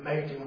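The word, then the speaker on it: Machen: Manchin was a theologian that battled bravely liberalism at Princeton Theological. – Seminary. – Yes. I'm probably mispronouncing it. Yes. – That's the way I've always heard it pronounced.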